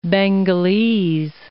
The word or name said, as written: Bengalese